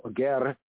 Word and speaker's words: ger: The word gear in the Hebrew, ger, we might pronounce it, put a little accent on it as ger in the tonation, but basically it's the word gear, sound like the English gear, a stranger.